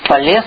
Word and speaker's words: Фалес: Фалес, ударение на последний слог, из города Милет.